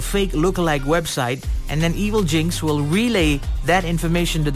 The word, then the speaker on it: Evilginx: It's called Evil Jinx. Yeah, it's spelled E-V-I-L-G-I-N-X.